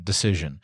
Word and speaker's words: Dacyczyn: If you're not familiar with Amy Decision, first of all, her name is spelled in a strange way, or in a an Eastern European way. I think her husband is Ukrainian. D-A-C-Y-C-Z-Y-N, Decision.